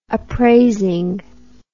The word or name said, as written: appraising